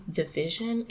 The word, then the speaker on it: DVSN: And I think it's Division. It's like DVSN. – Right. – I never know if I'm saying that right.